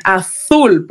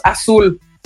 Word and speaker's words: azul: Cuando les enseñamos a, a, leer, si ustedes tienen primos, hermanitos o cuando ustedes eran pequeños, eh, ahí sí pronunciamos bien, azul. ¿Por qué? Porque queremos que el niño aprenda a escribir que azul es con Z. Pero luego ya en nuestro común hablar hemos perdido la manera correcta de pronunciar las letras y decimos azul.